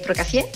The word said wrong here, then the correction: Prokofiev: ¿Algo por ahí? – Oscar, ¿está bien dicho? – Soy bailarina, sí. Prokofiev. – ¿Ve? Prokofiev.